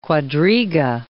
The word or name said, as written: quadriga